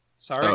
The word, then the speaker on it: Sarek: S-A-R-E-K? Sarek? – Oh, Sarek.